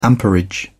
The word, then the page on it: amperage: British English